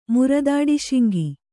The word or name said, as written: ಮುರದಾಡಿಶಿಂಗಿ